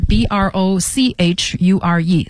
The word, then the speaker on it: brochure: 好，再往下呢是 brochure 啊，brochure 这个词呢是 b r o c h u r e c h 发诗的音，所以念成 brochure 小册子、说明书、产品宣传册都是 brochure。